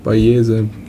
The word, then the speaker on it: Bayesian: Und nennt man Bayesian? Bayesian? Spricht man das so aus? Bayesian? Ja, so ähnlich.